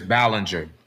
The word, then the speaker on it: Ballinger: Darius Ballinger. – Ballinger. – B-A-L-L-I-N-G-E-R.